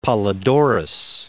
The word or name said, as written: Polydorus